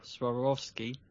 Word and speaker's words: Swarovski: They've also got a new collab now with Swarovski. Is that how you say it? – Yeah.